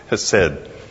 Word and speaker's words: chesed: It's the word chesed. Chesed, you know, Hebrew, kind of that combination of a language and a throat affliction.